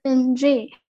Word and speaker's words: Ng: 对，我去这本书叫做 A Time of Lost Gods，它的作者叫做 Emily 嗯，它的那个姓其实是一个广东话的那个姓，就是吴的那个发音，就是 NG。